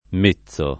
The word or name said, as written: mezzo